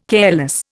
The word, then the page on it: careless: Indian